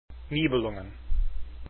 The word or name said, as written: nibelungen